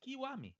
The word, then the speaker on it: Kiwami: Besides the fact that you don't say Kiwami, it's Kiwami. Kiwami. That's how it pronounce Japanese. Kiwami. Not Kwami. It was like Kwami.